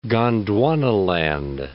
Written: Gondwanaland

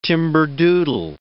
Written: timberdoodle

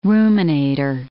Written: ruminator

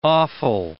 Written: offal

